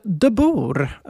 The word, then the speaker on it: De Burg: Ja, eller De Burg, alltså som de bor, franskt från början.